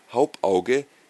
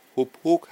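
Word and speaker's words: Hauppauge: Und er sagte wieder, nein, eine solche Karte von Hauptauge hätte er nicht. Und er sagte immer wieder Hauptauge und Hauptauge und Hauptauge, obwohl die Firma eigentlich Hupphook heißt. So spricht man die korrekt aus.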